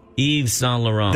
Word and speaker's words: Yves Saint Laurent: Yves Saint Laurent.